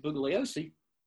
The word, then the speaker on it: Bugliosi: It's just something that, you know, people buy into the Helter Skelter myth, and they're really in the sense that Bugliosi, Silent G, which he was well to point out, yeah. Bugliosi used it.